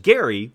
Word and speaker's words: Gerry: They requested that the Supreme Court use the proper pronunciation. You see, Marblehead, Massachusetts is where Elbridge Gerry was born.